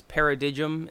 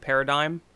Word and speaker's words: paradigm: Like when I, you know, at one point I thought it was paradigm instead of paradigm, because it's spelled paradigm, you know.